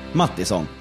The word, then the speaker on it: Mattisson: Henrik Mattisson. – Tack för att du uttalar mitt efternamn rätt.